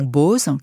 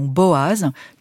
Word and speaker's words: Boaz: Et Victor Hugo a choisi la prononciation « Boz », qui est celle qui est fréquemment utilisée dans nos bibles modernes en français. Moi, pour ce carême protestant, j'ai choisi la prononciation « Boaz », qui est un peu plus proche de l'hébreu.